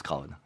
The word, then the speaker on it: Craon: Sur place on dit Crane, mais Crane, tout le monde, mais ailleurs on prononce Craon.